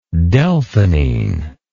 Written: delphinine

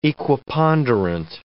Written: equiponderant

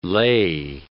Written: lay

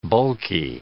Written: bulky

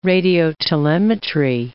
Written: radiotelemetry

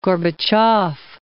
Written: Gorbachev

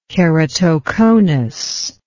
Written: keratoconus

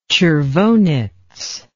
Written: tchervonetz

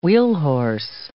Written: wheelhorse

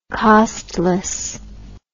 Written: costlessness

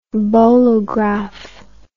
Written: bolograph

